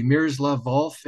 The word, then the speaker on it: Miroslav Volf: I'm hoping I'm pronouncing his name correctly, Miroslav Volf at Yale. – You pronounced it correctly, yes.